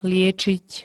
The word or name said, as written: liečiť